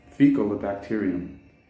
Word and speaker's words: Faecalibacterium: It should be fecal, fecal, bacteria, fecalibacteria, fecalibacterium prausnitzi, bacterium. But I think fecal bacterium kind of rolls better off the tongue.